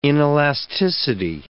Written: inelasticity

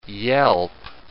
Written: yelp

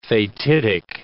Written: fatidic

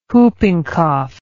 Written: whooping cough

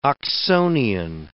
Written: Oxonian

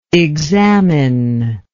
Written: examiningly

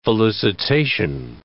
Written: felicitation

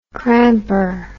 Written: cramper

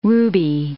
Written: ruby

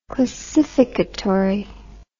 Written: clasificatorily

